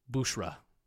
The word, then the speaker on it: Bushra: Sorry, I said her name wrong. Bushra.